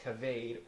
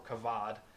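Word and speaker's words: kavod (כָּבוֹד): It's the Hebrew word kavod, spelled K-A-B-A-D. There's lots of lines and squiggles, but it's pronounced kavod or kavod.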